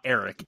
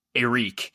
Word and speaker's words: Arik: Arik Gilbert, that's how I'm told it's pronounced. It's not Eric, it's Arik Gilbert, arrested early this morning on burglary charges.